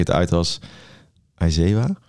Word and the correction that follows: Aizawa: Ja, Aizawa volgens mij.